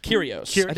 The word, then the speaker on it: Kyrgios: Kyrgios. The G is silent.